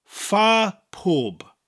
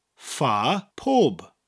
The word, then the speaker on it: fa pob: Fa is spelt double F-A and sounds like the English word far, far away, far from here. It sounds like that word. And pob, spelt P-O-B, sounds like the word probe, but without the R.